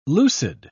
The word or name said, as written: lucid